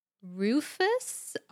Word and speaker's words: rufous: And the answer is Rufus, R-U-F-O-U-S. – Yeah, I Googled it to see if that's the correct pronunciation, and it is.